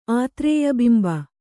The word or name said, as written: ಆತ್ರೇಯಬಿಂಬ